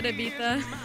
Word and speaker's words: Ibiza: I love that you said Ibiza. – Yes? – Yeah. – Yeah, it's correct. It is Ibiza.